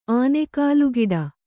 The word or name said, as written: ಆನೆಕಾಲುಗಿಡ